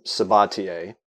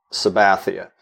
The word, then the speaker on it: Sabathia: Sabathia, uh, Louisiana, where uh, they still, they st- his his Louisiana relatives still pronounce the name Sabatier but when he when his parents migrated to California in the 30s and 40s, their families it got uh, sort of Spanishified, um, so that he and the family now pronounce it Sabathia,